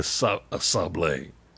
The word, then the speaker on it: sablé: Now, it's spelled S-A-B-L-E with an apostrophe over the E, so I guess it's sablé.